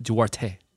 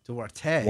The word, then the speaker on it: Duarte: And it's pronounced Duarte. – Duarte. – Duarte.